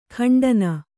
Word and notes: ಖಂಡನ: khaṇḍana

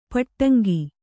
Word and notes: ಪಟ್ಟಂಗ: Kannada

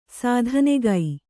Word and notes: ಸ್ಥಾನಿಕ: sthānika

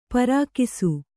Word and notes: ಪರ್ಕಿಸು: Kannada

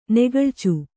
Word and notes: ನೆಗಳಿಚು: negaḷicu

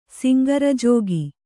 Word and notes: ಸಿಂಗರಜೋಗಿ: Kannada

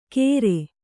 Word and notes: ಗೇರೆ: kannada

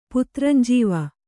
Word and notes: ಪುತ್ರಂಜೀವ: puntranjīvi

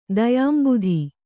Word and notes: ದಯಾಂಬುಧಿ: kannada

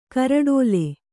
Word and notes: ಕಾರೊಡಲು: kāroḍalu

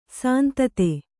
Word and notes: ಸಂತತಿ: santati

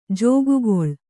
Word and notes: ಜಕ್ಕುಗೊಳ್: Kannada

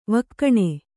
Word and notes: ವಿಗುಣ: viguṇa

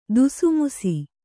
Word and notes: ಧುಸುಮುಸಿ: dhusumusi

